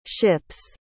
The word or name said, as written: ship's